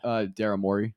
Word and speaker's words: Morey: Daryl Morey.